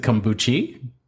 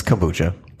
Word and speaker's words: kombucha: Is it kombuchi? – It's kombucha.